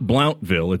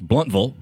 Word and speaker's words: Blountville: Now, and anything ending in V-I-L-L-E, why are you pronouncing it Blountville? It's not Blountville, it's Bluntville, and get it right.